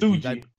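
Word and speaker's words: Tsuji: Yota I, Suji, isn't it? Suji. Suji. Oh, I've got your yeah, voice in my head. The T yeah. is silent.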